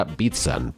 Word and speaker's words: Bican: La respuesta correcta, por supuesto, era Joseph Bican. Su apellido más bien se pronuncia Bitsan, porque es de origen checo, aunque todo el mundo le conocía por su apodo Pepi.